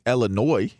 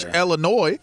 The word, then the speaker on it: Illinois: I mean, I'm just saying, you know, if it was Josh Illinois, everything would have worked out perfectly fine. – Silent S. – You know, the silent S.